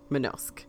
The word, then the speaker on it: Manosque: Manosque.